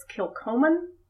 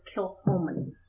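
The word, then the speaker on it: Kilchoman: It's spelled like you would think it's Kilcoman, but it's Kilhoman.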